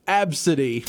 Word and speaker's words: Abcde: Then there's the one that was written A, B, C, D, E, pronounced Absidy.